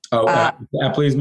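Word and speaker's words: Epley: I've done the, I'm going to pronounce it wrong, Epperle method. – Epperle's maneuver. That's good.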